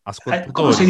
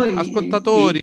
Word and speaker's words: ascoltatori: Ascoltatori. – dice? ¿Cómo se dice? Auditori, ¿no? – Ascoltatori, sí.